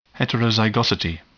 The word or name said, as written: heterozygosity